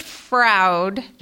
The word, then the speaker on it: Froud: It's Richard Froud. – Rhymes with crowd.